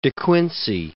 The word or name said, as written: De Quincey